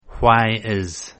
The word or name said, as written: why is